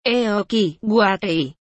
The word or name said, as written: Eocy Waei